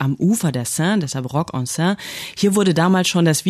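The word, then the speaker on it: Rock en Seine: Dort findet seit 2003 Ende August immer das Festival Rock en Sen statt.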